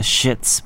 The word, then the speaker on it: Schitz: It's pronounced The Shits, but it's spelled S-C-H-I-T-Z.